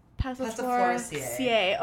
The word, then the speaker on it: Passifloraceae: Passifloraceae.